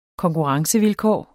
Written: konkurrencevilkår

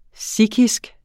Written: sikhisk

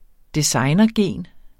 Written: designergen

